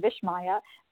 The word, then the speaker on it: Vismaya: So it's S-H-E-R-E-N-E, Shireen, and then Vishmaya, V-I-S-M-A-Y-A. There's no H in it, because it sounds like it, but it's an Indian name given to me by my Satguru Amachi.